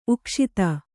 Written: ಉಕ್ಷಿತ

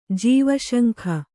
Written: ಜೀವಶಂಖ

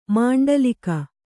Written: ಮಂಡಲೀಕ

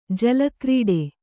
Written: ಜಲಕ್ರೀಡೆ

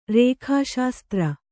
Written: ರೇಖಾಶಾಸ್ತ್ರ

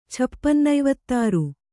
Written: ಛಪ್ಪನ್ನೈವತ್ತಾರು

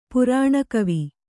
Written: ಪುರಾಣಕವಿ

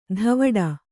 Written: ಧವಡ

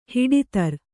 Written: ಹಿಡಿತರ್

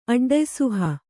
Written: ಅಡ್ಡಯಿಸುಹ

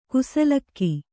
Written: ಕುಸಲಕ್ಕಿ

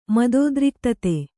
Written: ಮದೋದ್ರಿಕ್ತತೆ

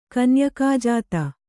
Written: ಕನ್ಯಕಾಜಾತ

